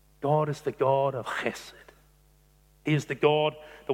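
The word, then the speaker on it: chesed (חסד): You've got to get a really big hoik in your mouth to say it properly. It is chesed.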